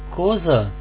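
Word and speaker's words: cosa: Между двух гласных она читается как «з». «Коза» – что?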